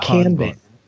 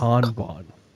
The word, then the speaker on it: Kanban: It's not Kanban. It's Kanban. – Thank you. – There's Kanban tacos? – Kanban tacos.